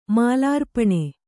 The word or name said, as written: ಮಾಲಾರ್ಪಣ